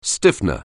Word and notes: stiffener: anglais

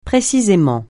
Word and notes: précisément: francais

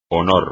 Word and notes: honor: espagnol

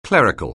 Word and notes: clerical: anglais

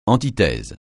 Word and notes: antithèse: francais

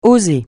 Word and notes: oser: francais

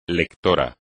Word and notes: lector: Spanish

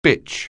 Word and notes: bitch: anglais

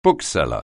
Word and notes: bookseller: anglais

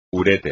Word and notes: uréter: espagnol

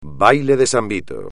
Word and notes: baile de San Vito: espagnol

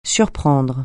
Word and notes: surprendre: French

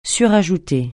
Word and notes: surajouter: French

